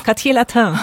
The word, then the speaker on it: Quartier Latin: Also ich würde sagen, das ist schon das Latin Quartieret. Habe ich es jetzt richtig ausgesprochen? – Das Quartier Latin.